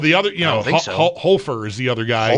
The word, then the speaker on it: Hofer: This guy's name is pronounced Hofer. – I think that's right. – It should be. – I think so. – H-O-F-E-R. It should be H-O-F-er.